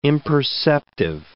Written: imperceptive

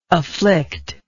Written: afflicter

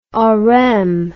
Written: Oresme

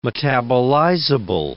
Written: metabolizable

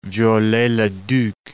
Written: Viollet-le-Duc

